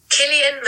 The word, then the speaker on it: Cillian: You know, on Google, you can search up how to say words, like how to pronounce Cillian Murphy. – Cillian Murphy. – Oh, Cillian. – Cillian. – Yeah, we're both wrong.